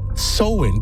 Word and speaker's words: Samhain: Well, to answer that, we got to go back centuries to an ancient Celtic festival, and it's pronounced Samhain, but it's spelled S-A-M-H-A-I-N.